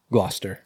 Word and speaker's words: Gloucester: Gloucester.